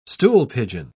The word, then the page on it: stoolpigeon: British English